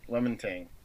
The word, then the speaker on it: LaMontagne: We caught a bit of Ray LaMontagne, I think is how you pronounce it. – LaMontagne.